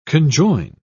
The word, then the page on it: conjoin: British English